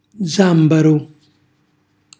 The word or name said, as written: zambarù